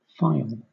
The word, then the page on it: phial: British English